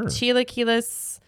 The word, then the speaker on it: Chilaquiles: I think it's pronounced, is it Chila, Chiaquiles? – Sure. – Chilaquiles uh, Verdes.